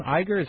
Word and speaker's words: Iger: Iger, is that how you pronounce it? – Yeah.